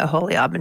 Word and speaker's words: Aholiab: And not only that, also nice job pronouncing a Aholiab and Bezalel.